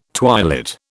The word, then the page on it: twilit: British English